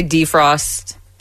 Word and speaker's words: defrost: I say defrost. I say defrost. I put the emphasis on the first syllable a lot. And yes, that is a very Southern thing.